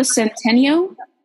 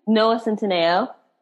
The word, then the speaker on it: Centineo: Is it Noah Centineo? – Noah Centineo. – Centineo. Okay.